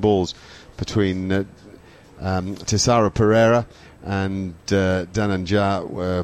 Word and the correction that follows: Dhananjaya: Yeah, Dhananjaya de Silva, thank you.